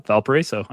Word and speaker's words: Valparaiso: Valparaiso? – Yep, there you go. Valparaiso.